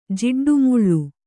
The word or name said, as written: ಜಿಡ್ಡುಮುಳ್ಳು